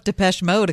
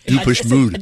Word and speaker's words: Depeche Mode: No, he's pr- mispronouncing them. – Yeah, I did deepish mood. I did Deepak mode.